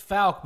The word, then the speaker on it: Fouke: Is it Fouke? – It's Fouke, but it's at least one person called it fuck in the movie.